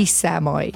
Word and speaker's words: Samai: So it's Matty Samai.